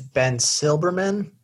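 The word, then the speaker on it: Ben Silbermann: I believe, correct me if I'm wrong, I think it's Ben Silberman.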